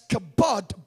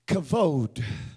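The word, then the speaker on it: kavod (כבוד): Now we pronounce it as kabod, but it's really in the Hebrew. When you speak Hebrew, you say it this way, kavod. Somebody say kavod. So it's not the kabod of God like we pronounce it in the English language. The proper pronunciation is the kavod of God.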